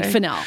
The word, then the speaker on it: Fennell: I feel like Fennell. I changed with the wind on this. Oh, excuse me, Fennell. I'm sorry. I'm not fancy enough to say her name right.